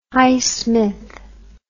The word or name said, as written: Highsmith